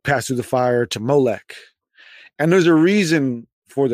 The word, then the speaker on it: Molech: And so they playfully uh, uh, um, changed the word, the Ammonite word Melech, the Ammonite usage of the word Melech to Molech and they use the same O pronunciation that they use for their word bosheth, which means mm-hmm.